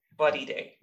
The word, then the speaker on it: Bodidey: B-O-D-I-D-E-Y, Body Day.